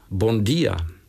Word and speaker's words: Bon dia: Bon dia. – To bylo super, řekl jste to naprosto správně.